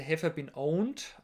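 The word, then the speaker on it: Have I Been Pwned: Und durch diese Webseite Have I Been Owned spricht man das aus.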